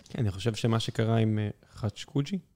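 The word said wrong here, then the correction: ח'אשוקג'י: איך מבטאים את השם? חשוקג'י. – חשוקג'י.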